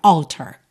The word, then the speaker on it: altar: Normalmente, a sílaba tônica é a primeira. Alter.